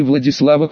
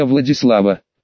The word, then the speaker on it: Владислав: «Секретно, лично для Владислава, по поводу прихода военных. Черт, в последний момент вспомнил, что в имени этого дурачка ударение надо ставить на «и».